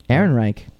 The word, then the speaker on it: Ehrenreich: Ehrenreich. – Yeah, Aaron? – Ehrenreich.